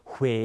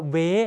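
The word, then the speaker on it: Huế: ส่งผลให้เวลาเราออกเสียงเนี่ยเราต้องหอปากด้วยนะครับดูตัวอย่างคำแรกพร้อมกันฮวาฮวาสังเกตปากผมห่อด้วยนะฮะฮวาแปลว่าดอกไม้นะครับยกตัวอย่างอีกคำหนึ่งนะครับคำว่าเหวหรือที่คนไทยเรียกว่าเวไม่คุ้นใช่ไหมฮะคำว่าเหวคำว่าเมืองเวนะครับภาษาเวียดนามที่ถูกต้องออกเสียงว่าเมืองเหวไม่ใช่เมืองเวนะครับเพราะว่าสังเกตตัวอูคือเสียงเลื่อนนะครับทำให้เราต้องออกเสียงห่อปาก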